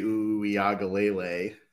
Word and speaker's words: Uiagalelei: I mean, I think DJ Uiagalelei.